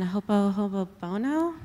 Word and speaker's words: ho'oponopono: Something I can also offer is metta meditation and ho'oponopono.